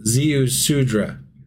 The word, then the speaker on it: Ziusudra: Sorry. Oh, no, that's what it rhymes with. Shit. No, that's not right. – Oh, my God. – Ziusudra. Ziusudra. – Ziusudra? Rhymes with mudra? – Ziusudra.